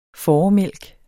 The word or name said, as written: fåremælk